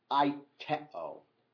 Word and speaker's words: aiteo: We are today going to look at the word ask, which in the Greek is the word aiteo. You don't need to know that, but it's the Greek verb aiteo. A-I-T-E, long O.